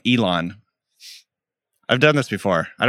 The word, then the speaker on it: Elon: It's like elongate Elon. His name. Okay. Elon. – Elon. Elongate it.